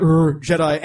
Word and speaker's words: Jedi: That's how you say it. Jedi.